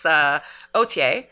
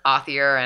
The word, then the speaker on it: Authier: And that's actually, everybody asks us this, it's just a play on our last name, which is spelled A-U-T-H-I-E-R. In French, Franco Ontario, it's Othier, but everywhere else it's kind of Othier and Author and Othier, and people just butchered it constantly.